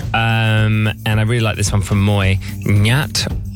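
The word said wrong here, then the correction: NatWest: Oh, no, I said it weird. It's Nat, isn't it? – Yes.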